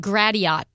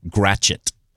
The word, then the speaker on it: Gratiot: It looks like Gratiot Street. – Oh. – What is that? – It's actually pronounced here Gratchet.